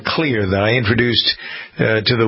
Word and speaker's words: Xlear: In this case, Clear is spelled X-L-E-A-R. Clear is a natural sinus care system that hydrates while it works against the contaminants. It is, the reason it's spelled X-L-E-R, E-A-R, is because the main ingredient is xylitol, which begins with an X.